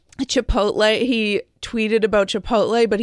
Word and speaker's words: Chipotle: No, no, it's Chipotle, Howie.